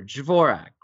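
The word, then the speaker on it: Dvořák: First, and I'm going to mispronounce this guy's name, so bear with me on this, but the great Czech composer, Dvorak, right? Is that fair enough way to say his name? – It's pretty close.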